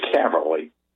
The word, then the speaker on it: Caverly: Lou Caverly. You spell that last name C-A- V as in Victor, E-R-L-Y.